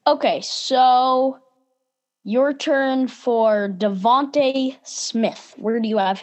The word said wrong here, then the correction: DeVonta: Isn't it pronounced Devonta Smith? I don't really know. – Oops. I don't actually know. Devonta Smith. Sure, it's Devonta Smith.